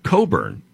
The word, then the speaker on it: Cockburn: Another one, COCKBURN, pronounced Coburn.